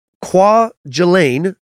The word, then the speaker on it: Kwajalein: By January 31st, Kwajalein, that's a tough one, was devastated. It doesn't look like it should be pronounced that way.